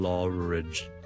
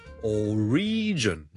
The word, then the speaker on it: origin: 所以呢我 single origin, single origin, single origin, single law, S-I-N-G-L-E, 个 L 扔埋落去 origin 变成 single origin, okay? That's number one. Number two 呢如果读成 origin 呢就会怪怪地啦。因为个重音其实 origin, O-R-I-G-I-N 个来源呢我哋个重音系第一个音节嘅。